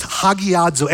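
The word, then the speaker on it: hagiadzo (ἁγιάζω): And this word hallowed here actually is the word hagiadzo. It's pronounced hagiadzo, and it means holy.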